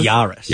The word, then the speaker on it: Yaris: Is that how they pronounce that? Yaris? Yaris? – Yaris.